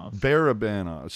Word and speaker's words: Barabanov: Am I pronouncing that right? Barbanov? – Barabanov. – So yeah, I'm like Barabanov, Barabanov.